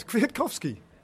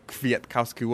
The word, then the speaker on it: Kwiatkowski: He has given us, he's broken it down into four syllables. Kwiatkowski. I can do all four syllables. – That's three syllables. – Michał Kwiatkowski. – No, Kwiatkowski. – It's not hard. Kwiatkowski.